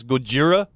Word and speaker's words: Gojira: Because that's originally what he was supposed to look like, Godzilla is the Americanized version of his name. What, we can't pronounce Gojira? – No.